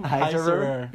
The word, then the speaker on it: Heisserer: Heisserer.